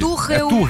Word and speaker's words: Tuchel: Tuchel, a pronúncia Tuchel, correta.